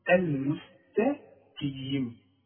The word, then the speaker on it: المستقيم: إنما ما نقولش بحرف مرقق أبدا. القاف من الحروف المفخمة. المستقيم.